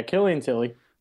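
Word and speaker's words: Killian Tillie: Killian Tille, I believe that is, did I pronounce his name right? – Yeah, Killian Tille.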